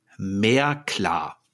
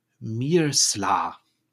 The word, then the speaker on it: Meerclar: Im Englischen heißt er aber, und ich buchstabiere das jetzt erstmal, M-E-E-R-C-L-A-R. Jetzt mehr klar. Jetzt wird aber innerhalb dieser Katzensprache das C wie ein S ausgesprochen. Und das lernt man auch, während man dieses Buch liest, bekommt man ein Gefühl dafür. Und diese Katzengottheit heißt also eigentlich Mirsla. Also nicht klar, sondern Sla.